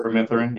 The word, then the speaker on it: permethrin: Permethrin, yeah.